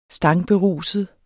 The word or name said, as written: stangberuset